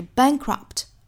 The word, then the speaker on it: bankrupt: 那还要注意一点呢，就是 bankrupt 这个 p 的发音我们不是爆破十足的，并且也不要拖音加元音，不是 bankrupt，应该是 bankrupt pt, pt, pt, bankrupt，相当于只是上下嘴唇闭一下去摆这个 p 的口型，然后就很快过渡到后面的 t。